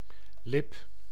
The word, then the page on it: lèvre: [lɛvʁ]; France